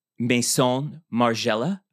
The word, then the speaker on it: Maison Margiela: So we have, I think this dress was actually Maison Margiela. – Oh. – Did I say it right? – Yeah.